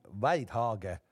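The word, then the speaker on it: Wydaeghe: Mart, ma, ma proovisin siis äh, saada siis ta uue kaardilugeja hääldust ka täpselt äh, kätte ja tegemist on siis nii-öelda flaami, flaamipärase nimega ja, ja tegelikult eh, mul on see hääldus küll nüüd olemas täna, aga ma ei tea, kas ma oskan selle välja öelda, et Martin Weidhage võiks olla siis see nii-öelda nimi, kellega ta sõitma läheb.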